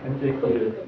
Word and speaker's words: Антиохия: Ой, проблемы с ударением у него. – Да, Антиохия. Да.